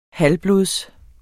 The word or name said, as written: halvblods